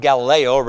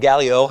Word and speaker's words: Gallio: His name is, again, I'm not sure it's pronounced Galileo, but Gallio, how his name is pronounced.